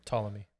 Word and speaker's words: Ptolemy: Ptolemy. – Ptolemy, yes. – Spelled with a P at the beginning.